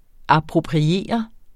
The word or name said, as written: appropriere